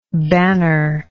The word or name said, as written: bannerlike